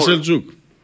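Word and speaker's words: Selçuk: Σελκούκ. Ναι, Σελτζούκ. – Καλά το λες, καλά το λες. Με σι είναι, αλλά προφέρεται Σελτζούκ.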